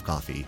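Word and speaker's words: Ko-fi: It's pronounced coffee, but it's K O F I, like Kofi Kingston.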